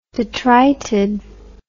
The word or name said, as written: detrited